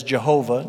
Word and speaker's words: Jehovah: Older tradition tended to pronounce this word as Jehovah. Newer tradition tends to pronounce it as Yahweh.